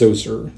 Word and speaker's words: Zoser: Spellings for this ancient Egyptian name vary, but I will pronounce it as Zoser, Z-O-S-E-R.